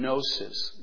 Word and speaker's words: gnosis: That's why the word Gnosticism, from gnosis, gnosis with a silent G in Greek, which is the idea of knowledge.